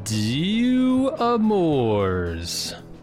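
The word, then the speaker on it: Deux Amours: And today we're talking Season 1, Episode 5 of Daryl Dixon, Dew Amours. That's how they say it over there in France.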